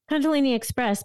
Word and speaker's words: Kundalini Express: Kundalini Express, but it's funny.